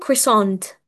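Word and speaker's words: croissant: طيب عندنا الكروسون اسمه كريساند، بالانجلش اسمه كريساند، كريساند it's spelled CRO I S S كمان A N T كريسانت، اللي هو كروسون مش اللي هو بيبقى ساندويتش بس شكله مختلف يبقى ملفوف كده، أكيد عارفين كروسون يعني.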